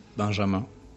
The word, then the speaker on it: Benjamin: So my name is Benjamin. We pronounce it Benjamin. – All right. – In French. – Benjamin. Benjamin. And so I'll call you Benjamin. – Benjamin is fine.